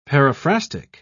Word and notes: paraphrastic: British English

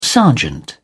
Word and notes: sergeant: British English

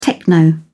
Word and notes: techno: British English

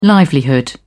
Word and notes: livelihood: British English

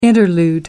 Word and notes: interlude: American English

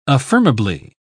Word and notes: affirmably: British English

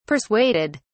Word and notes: persuaded: British English

